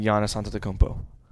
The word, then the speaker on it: Antetokounmpo: Kevin Durant is the best basketball player in the world, maybe second best player behind Giannis Antetokounmpo. I take KD. – Good pronunciation. Nice. – Oh, trust me, I know how to pronounce his name now.